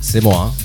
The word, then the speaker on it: C'moi: Tak Simoj, čtu správně tu přezdívku, je to, je to správně? – Jako, jak se to jako vyslovuje? Původně to mělo být asi Semoa. – Semoa.